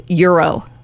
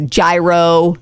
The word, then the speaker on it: gyro: And this last one, a lot of people struggle with, euro. – Euro. – People call it a gyro. – How is it spelled again? G-Y-R-O? – Yes, but it's pronounced euro.